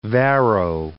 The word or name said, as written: Varro